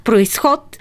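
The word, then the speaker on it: произход: Всъщност, думата, за която спорехте, происход, до съвсем скоро беше с ударение само на второто О. Но тъй като повечето хора я произнасят с ударението на И, то решиха да я направят дублетна.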